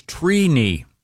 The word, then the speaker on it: Trini: The correct pronunciation is Trini. – Wow. – Trini. – Like T-R-E-E. – Like weenie. – Yeah, I you know, so is it phonetically T-R-E-E-K-N-I? – Yeah, phonetically it is T-R-E-E.